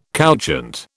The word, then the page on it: couchant: British English